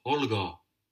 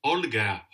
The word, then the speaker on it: Holger: Mein Name ist Holger. – Olga. – Holger. Holger. Wie man spricht man? – Helga. – Holger.